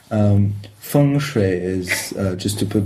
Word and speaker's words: feng shui: the place and why is it there was a temple there, and so probably feng shui is good, and lots of lots of other elements that come into it.